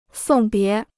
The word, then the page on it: 送别: sòng bié